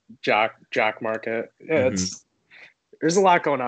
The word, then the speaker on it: Jock MKT: It's pronounced Jock Market, but you type Jock MKT onto the App Store.